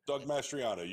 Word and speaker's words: Mastriano: Mastriano there. I can never say his name right. Is that it, Mastriano? – Doug Mastriano. You got it, brother.